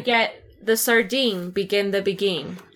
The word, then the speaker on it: beguine: I've heard begin the beguine before. And I guess they just like, rhymes with sardine.